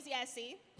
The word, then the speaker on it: Yessie: Yessie. My name's Yessie.